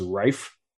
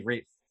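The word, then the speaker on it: Reiff: So the only reason why I ask is because, you know, earlier this summer I heard that Reef is really supposed to be pronounced Reif. So is it Reif or Reef? – So it depends on if he's German or not. – If he is. – Well, I've only ever heard him say his name Riley Reef, so I'm assuming he's not German.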